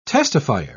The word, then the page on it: testifier: British English